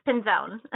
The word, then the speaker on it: Pinzon: Yes, Pinzon.